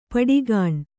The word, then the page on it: ಪಡಿಗಾಣ್: paḍigāṇ